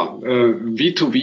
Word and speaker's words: V2V: Ich schätze mal, das wird Englisch ausgesprochen, sonst korrigieren Sie mich bitte. Ja, können Sie uns mal kurz erklären, was eigentlich hinter diesen Begriffen steckt? – Ja, V2V ist klassisch Englisch richtig ausgesprochen.